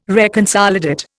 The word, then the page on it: reconsolidate: Indian